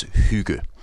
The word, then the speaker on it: hygge: Well, the way I define it, and maybe we should actually start with the pronunciation, it's hygge.